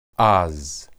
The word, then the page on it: az: Hebrew